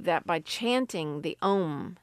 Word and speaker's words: Om: It's not unusual for a yoga class to begin and end the class with an Om chant. Again, this is based in Hinduism. When pronounced correctly, it actually consists of 4 syllables, A, U, M, and the last syllable is silent.